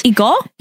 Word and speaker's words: 이거: And so then, the emphasis is on the E. – Yes. – 이거. – 이거. Mm. – Is there any other? – I guess the emphasis is on both syllables, really.